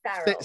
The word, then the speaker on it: Farrell: Did I say that right? – Yes, Farrell.